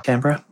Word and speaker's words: Canberra: Well, just recently, it got its first command from the dish down in Australia, Canberra. Is that how you say it? – Canberra, yeah.